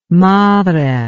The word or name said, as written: madre